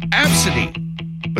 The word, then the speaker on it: Abcde: And they spelled it E-S-P-N. The number one worst name that somebody actually named their kid, it's pronounced Absidy. But they spell it A-B-C-D-E.